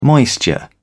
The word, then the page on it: moisture: British English